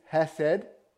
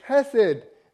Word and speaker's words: hesed: This word, if you haven't guessed already, is hesed. I know there's a K in front of it, but it's a silent K. It's hesed.